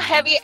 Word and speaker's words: jevi: Heavy. – And it's not heavy as in heavy in English. – Okay. How would you spell that? – Yeah. I would spell it J E V I.